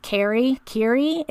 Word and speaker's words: Kiri: So her name is, I'm going to say her name is Kiri. It's K-I-R-I.